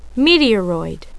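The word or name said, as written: meteoroid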